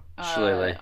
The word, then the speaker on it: Shillelagh: And then as a bonus action, I want to, I want to cast Shillelagh. Is that how you pronounce it? Shillelagh? – Shillelagh. Yep, you got it.